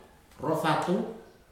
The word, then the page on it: rofatu: futuna aniwa